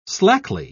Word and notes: slackly: British English

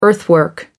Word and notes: earthwork: American English

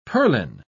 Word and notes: purlin: British English

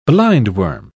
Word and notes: blindworm: British English

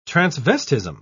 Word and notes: transvestism: British English

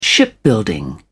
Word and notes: shipbuilding: British English

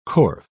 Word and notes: corf: British English

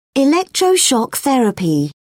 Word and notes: electroshock therapy: British English

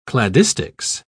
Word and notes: cladistics: British English

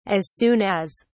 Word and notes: as soon as: British English

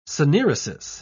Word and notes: syneresis: British English

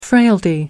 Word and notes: frailty: American English

